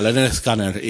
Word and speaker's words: Lynyrd Skynyrd: En el primer disco te dice cómo pronunciarlo, se llama Pronuncie Lynyrd Skynyrd, una cosa así. O sea que... Unos primeros discos de Leonard Skinner.